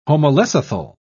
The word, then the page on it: homolecithal: British English